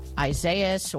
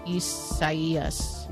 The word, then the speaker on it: Isaias: Well, no, but it's probably the Spanish rendering of it, which if that's the case, then the I is pronounced like an E. – Isaias.